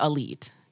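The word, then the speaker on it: Alite: Arif Ali, AKA Elite, which I fucked up on our reel, so I'm correcting it now. It's pronounced like Elite, guys. Elite with an A,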